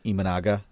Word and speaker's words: Imanaga: it's Shota Imanaga. Shout out to someone on Twitter that corrected us on the pronunciation there.